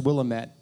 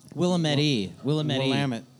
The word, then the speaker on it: Willamette: It's pronounced Willamette, by the way. – Willamette.